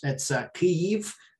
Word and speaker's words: Kyiv: It was, you know, part of it is because I grew up in Kiev, the capital. Now it's in Ukrainian, it's Kyiv, but Americans pronounce it Kyiv. But I'm going to keep saying Kyiv because that's how I grew up speaking.